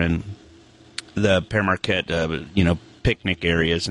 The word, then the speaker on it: Pere Marquette: Thank you for saying it right. Pere Marquette. Pere Everybody Marquette. Says Pierre Marquette.